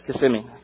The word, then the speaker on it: Kissimmee: So if you're from Florida and you ever go to Kissimmee, we know you're not from Florida. We know you have no idea how to pronounce that word. Yes, it's Kissimmee. A Floridian just told us.